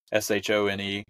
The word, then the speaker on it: Shone: by Tom Schoen, or Sean, depending on how he pronounces it. It's Shone.